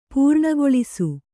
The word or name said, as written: ಪೂರ್ಣಗೊಳಿಸು